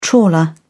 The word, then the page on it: trawler: British English